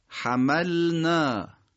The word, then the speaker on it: حملنا: کلمه حملنا مثل بعضی از کلمات دیگه، مثل انزلنا و کلمات دیگه ما اشاره کردیم، این لام و نون بعضن در بعضی از تلفظ ها ما ببینیم که یه مقدار سمت هم دیگه خلاصه دوست دارن قاطی بشن. حملنا نشه حملنا، قاطی نشه تو هم دیگه.